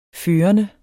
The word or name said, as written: førende